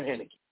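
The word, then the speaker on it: Heinicke: Because there's nowhere, and I believe I called the young man, Tyler Henke. I called him everything but his name, Taylor Henneke.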